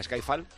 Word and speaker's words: Skyfall: ¿Has llamado Skyfall? ¿A Skyfall? – Skyfall. – Ah, se dice así, joder.